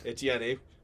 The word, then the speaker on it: Etienne: And then Travis Etienne, or I don't even think I pronounced his Etienne. – Name. Etienne.